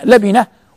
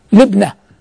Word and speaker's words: لبنة: وكلمة لبنة تنطق بفتح اللام وكسر الباء لبنة، وتنطق بكسر اللام وسكون الباء لبنة، وكلا الحالين صحيح، كلا قول اللفظين صحيح، إلا لبنة في زاوية من زواياها.